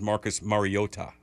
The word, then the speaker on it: Mariota: Like Marcus Mariota, his actual pronunciation of his name is Marcus Mariota.